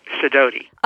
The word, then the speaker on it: Sidoti: Sidoti.